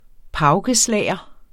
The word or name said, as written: paukeslager